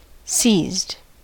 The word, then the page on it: seized: American English